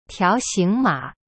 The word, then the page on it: 条形码: tiao xing ma